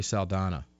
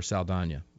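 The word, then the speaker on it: Saldaña: and it goes to California Democratic Assemblywoman Lori Saldana, or Saldana. I correct my pronunciation there.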